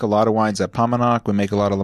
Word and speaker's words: Paumanok: So pop quiz across Palmer and Pomonok. – Thank you. My brain I'm not going to say it right.